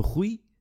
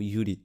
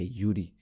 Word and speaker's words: Iúri: É, ou então, desculpa, Rui? Não, não, Yuri, não é Rui, é Yuri, ok? Com dois I's, super simples, I-U-R-I. Epá, é simples, não é?